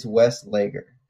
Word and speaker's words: Westlager: Is that how it's pronounced? – I say his name different every time, which I feel like is just a running joke with me now. But he says it's Westlager.